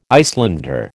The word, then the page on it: Icelander: American English